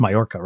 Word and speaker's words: Mallorca: Oh, that's Mallorca, right? That's how you say it.